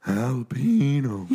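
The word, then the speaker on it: jalapeño: Ricky, the J is silent. It's jalapeno. – It's like an H.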